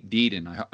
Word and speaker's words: Deaton: And to highlight that, I want to go back to an interview that you did, or a documentary, with Anthony Deaton.